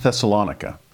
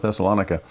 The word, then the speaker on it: Thessalonica: And today we are in Thessalonica, also pronounced Thessalonica or Thessaloniki, if you really want to get Greek, but we'll stick with the American pronunciation and go with Thessalonica.